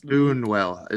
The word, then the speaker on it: Buñuel: Louis Buñuel is how I would say it.